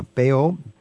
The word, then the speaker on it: Bayeux: Yeah, Bayeux.